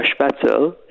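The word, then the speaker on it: Spätzle: So a spatzel, there's a sh in there.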